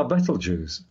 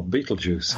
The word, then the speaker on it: Betelgeuse: Um, the, probably the most likely object to turn into a supernova in our galaxy at the moment is the star Betelgeuse or Betelgeuse, oh, however yes, you want to pronounce it.